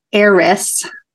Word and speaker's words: heiress: Like there are, and Sabrina, you know, is an heiress, silent H.